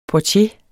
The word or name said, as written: portier